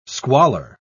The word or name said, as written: squalor